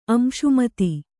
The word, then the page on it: ಅಂಶುಮತಿ: amśumati